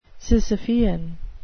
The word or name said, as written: sisyphean